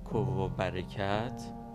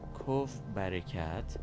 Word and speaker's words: CovBarekat: یکی یکی از این گوینده ها میگفت کوو برکت بله کوو برکت و یه گوینده دیگه ای هم میگفت کو برکت خب اینا هیچ کدوم درست نیستن در واقع تلفظ صحیح این واکسن نمونه ایرانی کوف کوف برکت